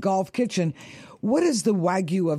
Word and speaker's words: Wagyu: And this is the Wagyu of Duck.